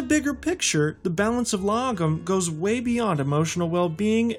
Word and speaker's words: lagom: It's logum. That's spelled L-A-G-O-M.